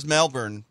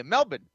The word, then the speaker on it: Melbourne: Well, I mean, what do you want from me? – That's how you say it in Australia, Melbourne. Well, well, you also say backyard in in Boston, but I'm not going to say backyard. So it, it, it's Melbourne.